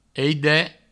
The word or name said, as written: aide